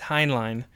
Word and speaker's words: Heinlein: No, I looked it up. The Robert Heinlein Society said, like, I did the little audio thing on there. It's Heinlein.